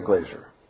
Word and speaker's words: Glaser: Because that's the way it's pronounced, Patty Glazer. Is it G-L-A-S-E-R or G-L-A-Z-E-R?